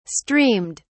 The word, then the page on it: streamed: British English